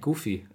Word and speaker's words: Goofy: Und ich sage immer noch Goofy, das heißt eigentlich Goofy. Habe ich lange gebraucht, bis mir jemand gesagt hat, dass der auch auf Deutsch nicht Goofy ausgesprochen wird.